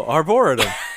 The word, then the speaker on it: arboretum: And that cemetery is also an arboretum. That's how you pronounce that word. How do you pronounce that word again? – No, I think you had it right. – Oh, arboretum.